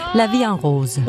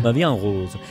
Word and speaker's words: La Vie en Rose: Edith Piaf, La Vie en Rose. – Yeah. – Som jeg overhovedet ikke udtalte rigtigt. – Nej. – Hvordan skal det siges? – La Vie en Rose. – La Vie en Rose.